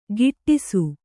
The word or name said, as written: ಗಿಟ್ಟಿಸು